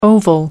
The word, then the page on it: oval: American English